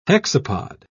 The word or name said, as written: hexapod